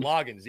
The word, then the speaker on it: Loggains: Loggins, yeah, Dowell Loggins.